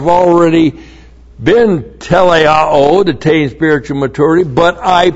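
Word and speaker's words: teleioō: The word made perfect is teleao. T-E-L-E-I-O, long O, teleao. It is from this verb teleao that we have teleos, T-E-L-E-I-O-S, and that is spiritual maturity.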